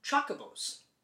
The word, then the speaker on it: Chocobo: But I also chalked it up to pronunciations like in Final Fantasy, there's, I always called them Kokobos. – Mm-hmm. – Spelt C-H-O-C-O-B-O. But I've heard people call them chocobos. Right? – Is the H silent or not? – Yeah, my argument was.